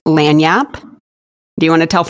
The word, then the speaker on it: lagniappe: It is pronounced Lanyap. So, it sounds like it would be spelled L-A-N-Y-A-P. But it is mm-hmm. spelled L-A-G-N-I-A-P-P-E.